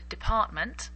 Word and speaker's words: department: It has two T's, but we usually only pronounce the last T. Department, department. The second T, well, really, it's slightly pronounced, but you can't really hear the T. Department. You almost stop instead of pronouncing the T.